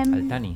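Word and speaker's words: Al-Thani: Mira, pero se equivocó también porque dijo eh, Tamim bin Hamad al-Sani y no es Tani. – ¿O cómo se dice? – Eh, se dice... – Al-Tani. O sea, nosotros Al-Tani.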